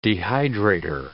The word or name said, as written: dehydrator